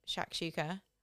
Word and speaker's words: shakshuka: So I always pick, and I'm going to say it right this time because I've also realised I've never said it right, shakshuka, which I've been calling shashushka for the last 30 years of my life.